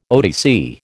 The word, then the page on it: OTC: American English